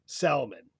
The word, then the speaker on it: salmon: So let's going to chuck back that L in salmon, even though no one in England pronounced it salmon. The L was stuck in to make it look more like Latin, which was the prestige language.